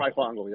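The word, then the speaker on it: Fryfogle: Ty Freifungel, is that how you pronounce it? The receiver for Indiana. Unbelievable. – Freifungel, yep.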